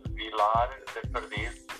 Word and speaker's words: Vilar de Perdiz: The village is called Vilar de Perdiz. That's in saying it, pronouncing it correctly in Portuguese.